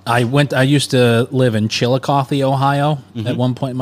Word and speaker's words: Chillicothe: And that's how you pronounce it? I've seen the spelling. It's Chillicothe. – It ends with a T-H-E, right? – Yeah. Chillicothe. – Chillicothe.